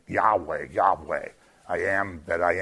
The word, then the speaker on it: Yahweh: So depending which vowels you put in it, but the accepted pronunciation is Yahweh.